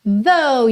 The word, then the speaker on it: though: Então, olha, though, essa palavra aí vou até soletrar para quem tá ouvindo no podcast, T-H-O-U-G-H. A pronúncia é though, com v, ok? Som aí nesse TH, a linguinha lá nos dentes da frente, though, tá?